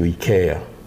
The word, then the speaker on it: IKEA: How do you say it here? IKEA? – IKEA. Yes. – I mean, I don't actually go there. But, you know, because it arose while I was in Spain, to me it's IKEA. – Right. – It's weird. Like, I think people must think I'm being pretentious. Like, oh, look. – It does sound pronouncing everything in Spanish.